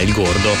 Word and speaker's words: El Gordo: e quindi era soprannominato El Gordo, o El Gordo, ecco, con la pronuncia corretta, El Gordo.